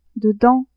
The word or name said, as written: dedans